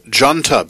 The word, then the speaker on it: Deanta: The Irish group Djanta before that, that name is spelled D-E-A-N-T-A,